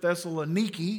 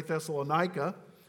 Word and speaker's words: Thessaloniki: It's really a brilliant summary from the Apostle Paul to the church at Thessaloniki. That's how you say it, but we say Thessalonica.